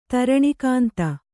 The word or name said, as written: ಧರಣೀಕಂದ